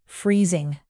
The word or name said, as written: freezing